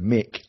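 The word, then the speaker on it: Mik: My name is spelt the way that all 80s pop stars did, so it's M-I-K, no C, so Mick, like Rick and Nick, I know. And then Scarlett, spelt like the colour, not double T, so S-C-A-R-L-E-T.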